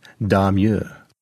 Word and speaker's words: Domergue: The movie's female lead, Dr. Ruth Adams, was played by Faith Domergue, although Hyacinth Bucket-like, she insisted that it should be pronounced Darmure.